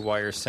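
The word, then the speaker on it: WIR: So the three would soon regroup as were, still pronounced Wire, but before the official announcement of Go To Bed's departure, Wire would put out the album The Drill.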